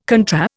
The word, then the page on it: contra: Indian